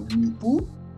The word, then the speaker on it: Mwepu: How is pronounced that man's last name? Is Enoch or Mwepu.